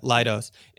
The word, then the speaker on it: Leidos: Yeah, Leidos.